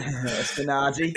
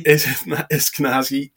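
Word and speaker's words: Eskinazi: So if you look at Perth at the moment, people might have Bancroft, or they might have, I can never say his name, Esniaki, is it? – Eskenazi. – Eskenazi.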